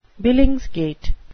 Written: billingsgate

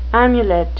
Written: amulet

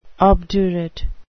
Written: obdurate

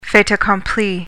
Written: fait accompli

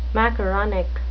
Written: macaronic

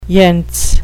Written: yentz

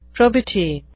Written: probity

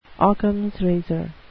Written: ockhams razor

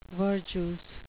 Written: verjuice

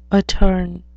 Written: attorn

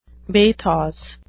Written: bathos

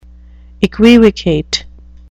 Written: equivocate

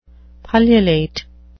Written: pullulate

